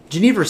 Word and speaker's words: Genever: And there's some discussion of how it's pronounced. Genever, Genever. Genever sounds right. – I've been correcting people all day long on this. And it's only because I went to this little class that I know how to pronounce it. Because it doesn't look like Genever.